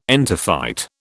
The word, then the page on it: entophyte: British English